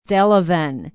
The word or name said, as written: Delavan